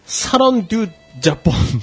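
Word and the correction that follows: Salon du Japon: 사론 듀 자폰 마에다.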